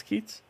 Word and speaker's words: Keats: Is it Keats or is it pronounced in a different way? – No, it's Keats. – Keats.